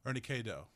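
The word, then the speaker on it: Ernie K-Doe: Ernie K. Doe.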